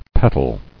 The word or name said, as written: Petaled